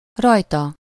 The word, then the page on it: her: /ˈhɜː(ɹ)/; /ə(ɹ)/; /ɚ/; /ˈhɝ/